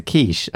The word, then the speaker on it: quiche: Does anyone fancy a quickie? – Excuse me? – Let's try and finish the podcast first. – No, it's a quiche. I mispronounced it.